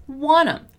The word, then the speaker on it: want to: Also, attention here to the pronunciation of want to, right? We normally say that as wanna.